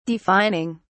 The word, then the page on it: defining: British English